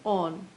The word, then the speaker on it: awn: So basically A-W-N, on is used by the poet in order to create rhyme with dawn.